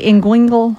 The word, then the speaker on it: inguinal: So we hear the term inguinal. And am I saying that correctly? Inguinal? – Yeah, it's hard to pronounce.